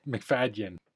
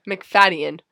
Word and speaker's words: McFadden: And it also features or stars Matthew McFadden as Mr. McFadden. There's a Y. There's a sneaky Y in there. – Oh, okay. So how do I say it again? – McFadden.